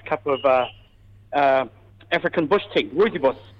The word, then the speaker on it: Rooibos: Rooibos. – Rooibos. – Rooibos.